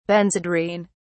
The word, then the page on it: Benzedrine: British English